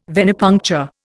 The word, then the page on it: venipuncture: Indian